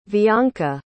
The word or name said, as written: vianca